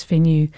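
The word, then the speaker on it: Fanø: I did my very best to check that pronunciation. I believe it's Finnu.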